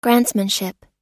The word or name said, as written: grantsmanship